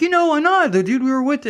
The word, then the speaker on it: Anand: He goes, you mean Anand? I'm like, whatever. Yeah, that's it. – Spell that for me. – A-N-A-N-D. – Oh, okay. – So it doesn't help. – I got called Otis the other day. – Otis? – Otis, yeah. A-N-D. That was a new one to me. – That is great. – My Midwestern pronouncing would be An-And. – Yeah, I've heard that too, An-And.